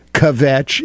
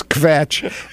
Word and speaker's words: kvetch: it is not a two-syllable word, kvetch, it's kvetch, and that really is a one-syllable word, which carries the import of kind of a sniveling kind of whining.